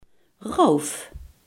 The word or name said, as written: roof